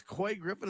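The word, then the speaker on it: Couy Griffin: I think it's Coy Griffin. Is that how you say it, Sheriff? – Yes. – C-O-U-Y, Cowie Griffin.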